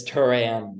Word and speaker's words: Turan: My last name is, it looks weird to pronounce until you hear it. It's Turan, like the band Duran Duran.